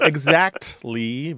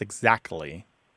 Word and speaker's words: exactly: Look, there's two common pronunciations of this word throughout the English-speaking world, no matter where English is spoken. And in your household, they both exist. Exactly with a T and exactly without the T sound.